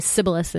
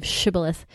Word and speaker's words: Shibboleth: In the next chapter, Jephthah captures a bunch of people and asks them to say this password, Shibboleth, but they can't say it because they can't say the sh syllable, so they're also murdered. They say Sibboleth instead of Shibboleth,